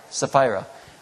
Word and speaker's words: Sapphira: Sapphira.